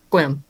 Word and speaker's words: Sequim: William Washington kills me too because it's spelled Sequim, but it's Quim.